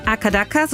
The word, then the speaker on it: AC/DC: Akadaka, so spricht man die Band AC/DC in Australien aus.